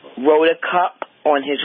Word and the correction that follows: rotator cuff: rotator cuff. Rotator cuff. – Yes. How do you pronounce that again, Dr. Wallach? – Rotator, like rotation, rotator? – Rotator.